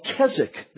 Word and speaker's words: Keswick: At least it has had tremendous popularity in evangelicalism, in our conservative churches, called Keswick, the Keswick movement. It looks like Keswick, because that's how we English, or Americans, I should say, would pronounce the word, K-E-S-W-I-C-K.